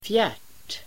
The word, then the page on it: fjärt: [fjärt:]